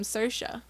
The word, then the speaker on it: Saoirse: I learned how to say her name, Saoirse.